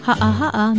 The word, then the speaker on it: ha'aha'a: Ha'aha'a means lowly, humble, unpretentious, modest, unassuming. Ha'aha'a is spelled with two okina or glottal stops, H-A-okina-a, and pronounced ha'aha'a.